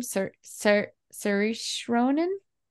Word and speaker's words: Saoirse Ronan: Saoirse Ronan. – Yeah. – How do you say her name? Do you know? – Saoirse Ronan. – Nailed it.